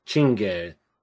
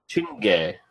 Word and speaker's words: Czinege: And you know, can you spell it? – Czinege. – Chinge. – Chinge. – The CZ is like a ch sound.